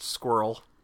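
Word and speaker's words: Sqürl: Or that it was a cover by Jim Jarmusch's band, which I believe is pronounced Squirrel. – Yes. – It's like S-Q-U with an umlaut, R-L, all lowercase, because artsy.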